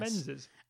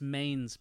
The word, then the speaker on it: Mesnes: The reason a lot of people struggle with the word, it's spelled M-E-S-N-E-S. – Menzies. – And no, it's Maine's Park. – I said Maine's. – No, you didn't. You said Men's Park.